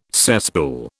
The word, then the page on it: cesspool: British English